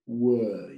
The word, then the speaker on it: were: Because where and where, muchos lo pronunciamos igual. El where, W-H, ¿dónde? Y el were, el pasado de was en plural. ¿Puedes pronunciarlo despacito para que los oyentes lo oigan? – Claro, sí. Where were you? – So if I'm right, you open your mouth more for where, donde, and you close it a little more for were.